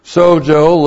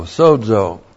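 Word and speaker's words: Sozo (σῴζω): Sozo looks like this in the Greek. That's S long O, Z long O. Sozo.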